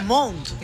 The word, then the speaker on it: Le Monde: Le Monde.